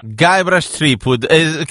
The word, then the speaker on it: Guybrush Threepwood: Guybrush Threepwood, credo che spero che la pronuncia sia esatta, assolutamente sì.